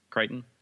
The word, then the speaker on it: Crichton: And apparently this is based off of Michael, how do you say his last name? Crichton? – Yeah.